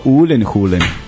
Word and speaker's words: Gyllenhaal: I onda imaš njegove klipove na YouTube-u gde kaže kako se preizgovara tvoje i prezime i onda je on rekao Ulenhulen. – Ulenhulen, tako se izgovara kao Gaelic.